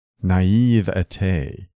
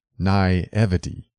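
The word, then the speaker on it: naïveté: Naivety is pronounced naivete, not naivety.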